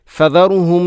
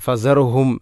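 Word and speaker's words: فذرهم: 42-ാമധ്യായത്തിൽ ഫദറുഹും യഖൂദു വയൽഅബു, ഇവിടെ ഫദറുഹും ഫദറുഹും ഫസ അല്ല ഫദ, ഇവിടെ ദാലിന് പലപ്പോഴും നമ്മൾ ഉച്ചാരണത്തിൽ ഫസറുഹും സായോട് സാമ്യമുള്ള ഒരു ശബ്ദമാണ് പലപ്പോഴും നമ്മളിൽ നിന്നും കേൾക്കാറുള്ളത്. ആ ഭാഗം ശ്രദ്ധിക്കണം. ഫദറുഹും, അതുപോലെ തന്നെ യഹൂദു യൗമുഹുമുല്ലദി, അങ്ങനെ ഓതുന്നത് ശ്രദ്ധിക്കേണ്ടതാണ്.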